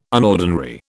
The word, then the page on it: unordinary: British English